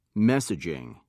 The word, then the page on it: messaging: British English